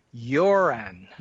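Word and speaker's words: Göran: it's pronounced a little more like Joran.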